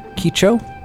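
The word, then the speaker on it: Kidjo: was Angelique Kicho.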